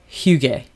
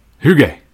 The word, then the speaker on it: Hygge: It's called Hygge. – Hygge. – Hygge. It's spelled H-Y-G-G-E.